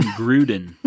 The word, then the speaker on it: Groudon: It's actually pronounced Kyogre and Gruden.